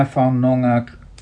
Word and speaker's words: Whanonga: And Timana Whanaonga, how do you pronounce that? Whanonga. Whanonga, is that how you pronounce it? – I've never looked. – Yeah, go to the expert, the pronunciation expert. – Whanonga.